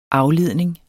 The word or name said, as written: afledning